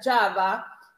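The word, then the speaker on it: Java (חַוָּה): Eva, que en hebreo se pronuncia Java.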